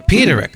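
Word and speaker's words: Peterik: Jim Peternick? – Peterick, I'm sorry, from Ides of March from Survivor.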